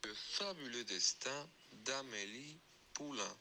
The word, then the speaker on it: Le fabuleux destin d'Amélie Poulain: Le fabuleux destin d'Amélie Poulain.